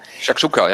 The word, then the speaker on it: Shakshuka: Ist man zum Frühstück ich weiß nicht, ob ich das richtig ausspreche, Shakshuka. – Shakshuka, ja.